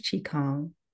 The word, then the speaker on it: Qigong: Please tell us for the people who don't know, what is Qigong? – Qigong very well, very good pronunciation.